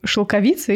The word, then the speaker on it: шелковица: Нормальный тутовник, шел, шелковица или тутовник. Все, мы погуглили. Теперь мы знаем, где ставить ударение. Шелковица.